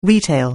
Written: retail

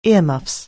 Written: earmuffs

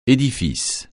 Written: édifice

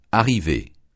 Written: arriver

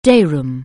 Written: day room

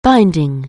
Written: binding

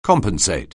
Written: compensate

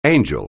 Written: angel

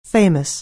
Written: famous

